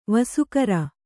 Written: ವಾಸಾಗಾರ

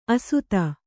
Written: ಅಸಾಧು

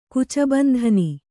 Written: ಕುಚಬಂಧನಿ

